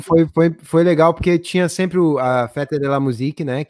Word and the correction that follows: Fête de la Musique: é Fête de la Musique.